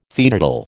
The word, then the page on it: teetotal: American English